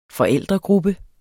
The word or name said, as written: forældregruppe